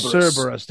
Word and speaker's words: Cerberus: It's called Odious and Cerberus. Tell me how to pronounce it. – Cerberus. – There you go.